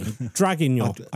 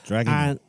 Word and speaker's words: Draguignan: I'm going to call it Draguignan. I have done, you know how you could go on YouTube and you can check how to pronounce these things. I've done it, but my French accent is appalling. Draguignan, I think is how you say it.